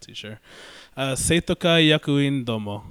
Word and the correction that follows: Seitokai Yakuin Domo: An anime that S-Y-D. – I finally figured out. Seitokai Yakuin Domo. – Seitokai Yakuin Domo.